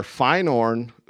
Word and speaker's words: Feyenoord: Feyenoord, who is another team in the Dutch league, said, we'll give you that deal.